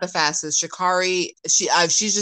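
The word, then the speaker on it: Sha'Carri: How do you say it? Shakari? – I think it's Shakari.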